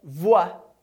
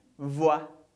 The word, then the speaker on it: voix: Segunda é voix. Simplesmente voix.